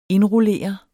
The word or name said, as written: indrullere